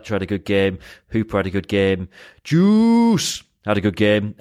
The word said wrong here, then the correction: Joost: Uh, although we subsequently learned that's not how you pronounce his name, which is a bit of a gutter, really. Uh, so sorry, J- Joust had a, a good game. Uh, so it's Joust, Joust isn't it? Joust.